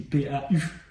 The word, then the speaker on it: Pau: Alors, attention, il y a un jeu de mots, ça s'écrit P-A-U, comme la ville.